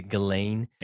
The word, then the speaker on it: Ghislaine: I never know how to say her name, because I don't watch news. Ghislaine. Is it Ghislaine? – Yeah.